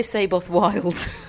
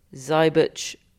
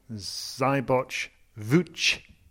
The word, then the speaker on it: Seyboth Wild: He lost to Thiago Zybich-Wilj, who you might formerly have known, because we've been calling him such, as Thiago Saboth-Wild. Until 24 hours ago, but we've all done some heavy listening to his voice note on the ATP Media bio, and we really, really are trying to get his name right, and we hear it as Thiago Zybich-Wilj. You've been saying it on radio all day. – I was told to call him Thiago Zybich-Wilj.